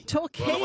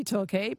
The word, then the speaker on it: Torquay: Torquay. – Well, it's spelt talk on my screen, like motor talk, but I thought, it might be Torquay, but okay.